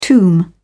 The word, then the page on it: tomb: British English